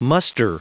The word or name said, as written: muster